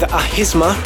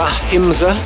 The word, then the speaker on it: Ahimsa: Forthcoming on In Trance We Trust, this is Menno de Jong with Ahisma, or Ahimsa, depending on whether or not you're going to pronounce it correctly.